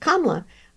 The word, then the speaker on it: Kamala: I know someone whose name is Kamala. That's how she says her name. But Kamala Harris pronounces it Kamala.